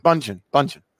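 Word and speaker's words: Bündchen: I think it's Bunchin. – Bunchin. – That's how you say that? – Yeah, Bunchin. – Oh, silent D.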